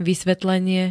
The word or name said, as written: vysvetlenie